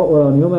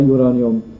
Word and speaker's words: اورانیوم: مثال دیگری بزنیم از عنصر اورانیوم، این بعضی جاها اورانیوم هست بعضی جاها یورانیوم، تلفظ انگلیسیش یورانیومه تلفظ فرانسویش اورانیومه.